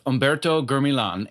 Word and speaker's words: Humberto Gurmilan: I'm here today with Humberto Gurmilan,